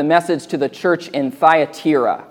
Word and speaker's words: Thyatira: I think that's how you say that. I've, lis- I've, listened to a bunch of pastors say it, they all say it different ways. The official pronunciation on YouTube is Thyatira.